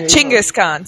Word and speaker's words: Chinggis Khan: Genghis Khan? Yeah. – Yeah, Chinggis Khan. Say it right.